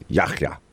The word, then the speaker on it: Yahya: He's using just, I think, the standard English pronunciation. But when I looked at this a couple hours ago, I looked up the proper way to pronounce it in Arabic, and it's in Yahya.